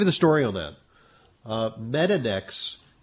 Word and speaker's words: Metanx: Hi, I wanted to ask your opinion on Metanex, M-E-T-A-N-X.